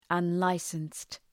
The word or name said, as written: unlicensed